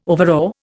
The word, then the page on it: overawe: Indian